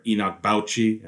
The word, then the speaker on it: Enoch Bauchi: But definitely want to talk about a crown jewel of the recruiting class, and that is Canadian center Enoch Bauchi. And yes, I am pronouncing that name right.